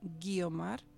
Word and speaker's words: Guiomar: Guiomar se escribe G-U-I, Guiomar.